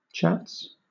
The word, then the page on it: chats: British English